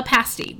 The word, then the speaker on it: pasty: Um, uh, before that, uh, we get a lowdown on the history of Moose of the Moose County food, including that staple, the pasty, which rhymes with nasty.